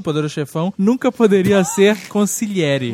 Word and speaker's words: consigliere: Assim, no mesmo nível do sotocapo, tem o consigliere. Que é uma palavra tão simples, mas que ninguém consegue falar. – Consigliere. – Mas, na verdade, eu acho que nem tem esse G, né? Na pronúncia.